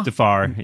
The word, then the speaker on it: Mustafar: Mustafar.